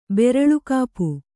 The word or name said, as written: ಬೆರಳುಕಾಪು